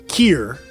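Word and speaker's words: Kir: And then I fucking listened to some YouTube videos, and it's indeed called Kier. – Kier. – Because it's French.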